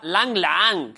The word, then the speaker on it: Lang Lang: Que, por cierto, en Named de Named hace poco vino Lang Lang, el pianista, un pianista chino, y nos dijo que se pronunciaba Lang Lang, que era distinto, pero en Named de Named...